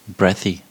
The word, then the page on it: breathy: American English